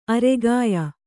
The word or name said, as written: ಆರೋಗ್ಯ